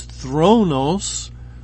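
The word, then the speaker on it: thronos (θρόνος): It's the Greek word we would pronounce as thronos, and you can hear the word throne.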